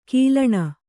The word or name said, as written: ಘಲ್ಲಣಿ